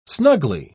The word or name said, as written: snugly